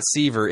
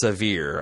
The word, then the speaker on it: Sevier: It's not Seaver. It's Sevier, I found out.